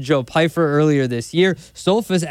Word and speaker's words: Stoltzfus: Am I saying that right? Stoltzfus? Uh-huh. All right, Stoltzfus here.